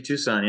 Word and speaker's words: Tucson: FC Tucson, yeah.